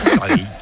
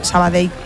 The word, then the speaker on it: Sabadell: El cachondo de Kiki Lesa dice, pronuncias muy bien Sabadell. Mis respetos. – Sabadell. A mí me lo decían mucho cuando estaba en Primera Federación porque yo también decía Sabadell, que es como se dice.